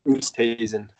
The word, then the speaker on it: Oosthuizen: You have Lewis Oosthuizen. Did I say that right? – Oosthuizen.